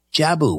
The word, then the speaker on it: Jabu: Jabu, welcome to Coast. – Yeah, you said that perfect. Just like Jabu the Hutt, but with a long U, Jabu.